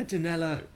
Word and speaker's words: Donella: Yeah, Donella.